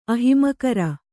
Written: ಅಹಿಮಕರ

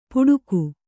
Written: ಪುಡುಕು

